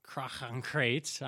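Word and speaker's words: Crach an Craite: So as you arrive, the king of Skellige, Krachankrate, I'm just going to say it that way, I've checked my dictionary. That is exactly how you pronounce it.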